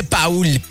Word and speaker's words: Paul: Y también hay que seguirnos tú y yo, Paul GS. Recuerda que mi nombre se escribe Paul, Paul GS, así, Paul GS en Instagram y TikTok, ¿ok?